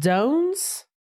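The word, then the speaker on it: Toan's: I believe it's pronounced Domes. – Domes? – T-O-A-N apostrophe S. – Domes, kind of like the D version of Joan.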